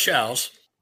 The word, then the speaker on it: Shows: Even though it's pronounced Shouse, I'm the most important person in here, and you're gonna do what I tell you to do.